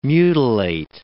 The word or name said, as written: mutilate